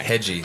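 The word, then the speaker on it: Hegyi: Hedgie. – That's how you pronounce it. – Rhymes with this guy. Rhymes with wedgie.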